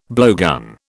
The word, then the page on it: blowgun: British English